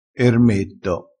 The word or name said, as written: ermetto